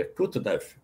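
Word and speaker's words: Kutuzov: é Kutunev mesmo, se escreve Kutunov.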